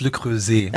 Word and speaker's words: Le Creuset: Es heißt Le Creuset. – Ah, Le Creuset, Entschuldigung bitte.